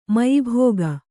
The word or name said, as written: ಮಯಿಭೋಗ